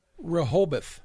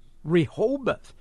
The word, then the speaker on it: Rehoboth: How did you pronounce it? Rehoboth or Rebo? – Rehoboth. – Rehoboth. Rehoboth. Okay.